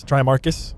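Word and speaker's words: Tremarcus: So is it Trimarcus? C-U-S, Trimarcus? – Yes.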